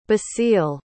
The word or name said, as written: basile